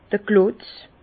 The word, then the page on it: d'r Klotz: Bas-Rhin; Herrlisheim